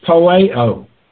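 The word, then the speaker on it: poieo: The word purposed is poeo, P-O-I-E, long O.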